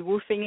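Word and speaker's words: WWOOFing: So woofing stands for, it's spelled WWOOF. Stands for Worldwide Opportunities on Organic Farms.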